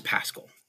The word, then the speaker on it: Pascal: So I think the only person that's been able to pronounce it properly before was Pierce from Paizo, because he went to his high school was something or other Pascal High School. And I was like, wow, that's really weird. But yeah, so it's Pascal. That's how you pronounce my name.